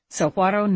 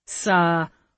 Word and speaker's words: Saguaro: Sahuaro. Matt and I have been calling it Sahuaro. We forgot the rule that G-U-A is pronounced like wa, with heavy breathing.